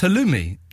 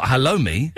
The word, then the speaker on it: halloumi: But it's pronounced halloumi, not halloumi.